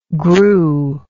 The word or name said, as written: grue